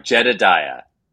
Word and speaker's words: Jedidiah: Also, how do you say your first name? Is it Jedidiah? – It's hard. Jedidiah. – Jedidiah.